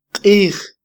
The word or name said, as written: qij